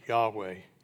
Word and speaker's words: Yahweh: And so the nearest we can figure out today how that his name is pronounced is Yahweh.